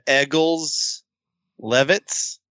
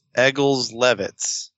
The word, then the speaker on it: Egils Levits: Yeah, Egils Levits, Egils. Last name Levits. Egils Levits.